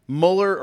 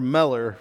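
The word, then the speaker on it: Müller: And so his actual pronunciation, this is what he looked like later in life, would be Mueller or Meller, really depending on what person you read.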